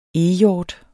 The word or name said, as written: eghjort